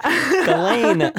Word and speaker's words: Ghislaine: She's like, me and whatever her Giselle is. – Ghislaine. Ghislaine.